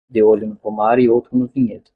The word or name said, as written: olho